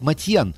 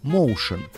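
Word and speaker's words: Motian: Кстати, в армянской семье Пола фамилию произносили именно как Матьян, но сам Пол ее произносит как Моушен. Стремление к фонетическому сходству с английским «моушен» – движение.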